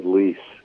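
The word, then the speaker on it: Leisz: And the other thing was my dear friend is a guy, multi-instrumentalist named Greg Leese.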